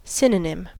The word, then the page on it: synonym: American English